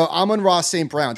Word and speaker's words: Amon-Ra St. Brown: So Amon-Ra St. Brown.